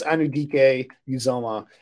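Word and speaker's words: Anudike-Uzomah: Um, I look at uh, Felix Azumo Uzama, I think, from Kansas State. Let me get the name right here because he's a guy that, yeah, Felix Anudike Uzama um, from Kansas State.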